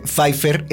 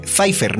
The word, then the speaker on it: Pfeiffer: O sea, que se dice Pfeiffer, no Pfeiffer, porque la pronunciación alemana es Pfeiffer. O sea que sí, digamos decir nosotros a mexicanizarla, Pfeiffer.